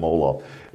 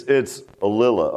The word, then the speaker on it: Ollila: I know Brother Gibbs was pronouncing his last name wrong. He was calling him Olaf. It's Alila, O-L-L-I-L-A.